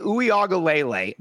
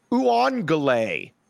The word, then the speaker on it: Uiagalelei: Then DJ Uiagalelei, this is not hard to pronounce. Tai Uiagalelei, not hard to pronounce. Uangale.